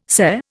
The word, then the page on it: SCE: Indian